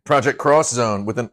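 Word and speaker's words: Project X Zone: Project Cross Zone but it was an X pronounced cross.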